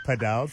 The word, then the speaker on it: Padel: Which is how you pronounce it, by the way. It is Padel, so that voice note was 100% correct.